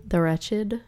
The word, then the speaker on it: The Wretched: It is pronounced that way, right? The wretched. The wretched? – The wretched. – The wretched. Yeah. – What was You're right. Not a ratchet, like a rat, like a rack or like a.